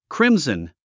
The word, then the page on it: crimson: krímzn; クリムズン